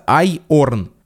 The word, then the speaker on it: iron: Оно пишется iron, а читается «айорн». В то же время есть слово irony, которое читается по-человечески. Irony. Сначала I, потом ро, а не айор.